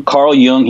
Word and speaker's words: Jung: Jungian, Carl Jung, he's a Jungian psychologist.